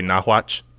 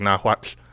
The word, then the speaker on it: Nahuatl: The TL there is like sort of a back-of-the-throat sound. Like, I want to say Nahuatl, but it's not. It's like Nahuatl.